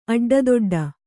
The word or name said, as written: ಅಡ್ಡದುಡ್ಡು